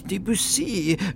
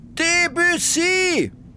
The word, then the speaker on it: Debussy: Das heißt natürlich Debussy. Man muss es französisch aussprechen. Debussy, schrie sie Custus da Silva zu. – Debussy? Debussy!